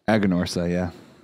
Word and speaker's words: Aganorsa: This is Aganorsa leaf. Aganorsa leaf. – Aganorsa, yeah.